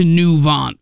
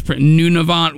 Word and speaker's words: Nunavut: You follow my army up to Nuvant, which, for those of you who don't know, is the very Arctic territory, and I'm pronouncing it completely wrong, but I don't know how it's Nunavut, whatever.